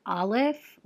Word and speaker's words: Aleph: The way that Signs and Wonders describes it as is that Aleph, which that's how you pronounce it.